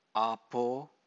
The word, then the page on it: apo: Pali